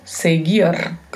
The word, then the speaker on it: seguir: Veja bem a pronúncia, verbo seguir, sempre com o R vibrato ao final.